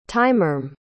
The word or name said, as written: tymur